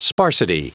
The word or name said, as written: sparsity